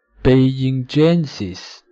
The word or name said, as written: beijingensis